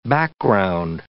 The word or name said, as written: background